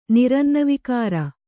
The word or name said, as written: ನಿರನ್ನವಿಕಾರ